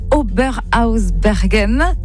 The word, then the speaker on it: Oberhausbergen: Vous avez donc tourné en Alsace, notamment à Oberhausbergen. – Bravo. – C'est bien prononcé, c'est ça? C'est très bien prononcé.